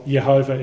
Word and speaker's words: Jehovah: And so we will stick with the pronunciation Jehovah or Yehovah if we want to have a Y sound for the J.